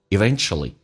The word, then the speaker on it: eventually: Eventually 美式发音当中这个 u 可以把 u 去掉直接发成 a Eventually 最终 Good stuffStarted coming back out.